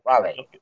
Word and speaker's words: Huawei: You could argue that this company, am I pronouncing it right, Huawei? Yahweh, yeah, that's right. – Huawei.